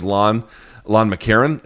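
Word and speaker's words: McEachern: His name is Lon McCarron. It's spelled Lon yes. McKeachern, but it's pronounced Lon McCarron.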